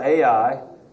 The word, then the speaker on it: Ai: The thing about Ai that I love about it is we call it Ai and it's spelled A-I.